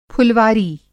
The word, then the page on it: फुलवारी: پھلواری; Hindi